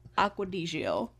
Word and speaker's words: Acqua di Gio: It's spelled Acqua D-I space G-I-O. Acqua di Gio.